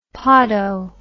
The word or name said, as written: podo